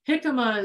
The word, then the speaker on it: jicama: Jicama is spelled J-I-C-M-A, jicama. But it you need to take the skin off the jicama, because it does have a skin like a potato skin or whatever.